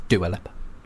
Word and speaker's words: Dua Lipa: She got really upset because people in the media kept pronouncing her name wrong. There's a really good fiery interview with her about it. It's good. She's like, it's Dua Lipa.